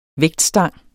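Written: vægtstang

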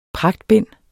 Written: pragtbind

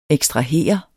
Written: ekstrahere

